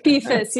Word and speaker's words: Befus: Befus, yeah.